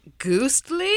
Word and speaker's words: goostly: So that is spelled G-O-O-S-T-L-Y. – Ghostly? – Ghostly. – Ghostly? – It's wonderful. – Like in the, that's right in the middle of the Great Vowel Shift.